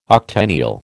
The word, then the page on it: octennial: American English